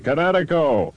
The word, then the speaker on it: Kinetico: Everybody knows that Kinetico is spelled K-I-N-E-T-I-C-O. – I thought the instructor said Connecticut. That means coast. – Son, you should have remembered kinetic as in kinetic energy with an O on the end. Kinetico. – Kinetico. Spell it K-I-N-E-T-I-C-O.